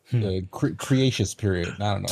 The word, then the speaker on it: Cretaceous: Cretaceous period. – get now you got me. Now you got me messed up. – Cretaceous. – Yeah, I said it right.